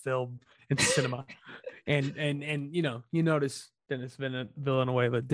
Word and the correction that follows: Denis Villeneuve: Matt doesn't care for him so much he mispronounced both of his names, Denis Villeneuve.